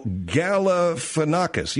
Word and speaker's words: Galifianakis: I'm not even pronouncing it right. Oh, Galifianakis. Yeah, Zach Galifianakis.